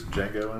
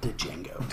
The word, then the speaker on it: Django: Predators, Django Unchained. Yeah. – It's pronounced Django. – Django, the D is silent.